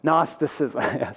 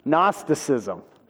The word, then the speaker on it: Gnosticism: Starts with a G that you don't pronounce. Gnosticism. Starts with a G, but that doesn't really help. Gnosticism.